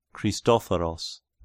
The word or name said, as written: Christophoros